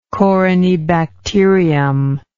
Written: corynebacterial

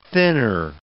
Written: thinner

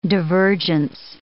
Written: divergence

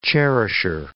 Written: cherisher

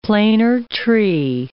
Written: planer tree

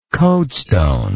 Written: coadestone

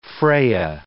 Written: Freya

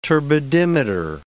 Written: turbidimeter

